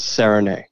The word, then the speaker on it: Serigné: Okay, so you know it's a French name and it looks a little bit confusing there at the end, but you pronounce it Serenay. – Serenay. Okay. – And the way to remember that is like when you serenade someone, just get rid of the D, and that's the way it sounds.